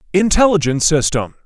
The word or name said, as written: Intelligent system